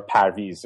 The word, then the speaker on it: Parviz: It's a traditional name, and um, my great grandfather's name was Parviz, and he was actually, or Parviz, uh, you know, in Persian you would pronounce it that way.